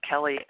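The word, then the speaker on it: Kelley: This is Kelly S. from Oklahoma, and like Lesa, my name is spelled different, as you guys know, E-Y.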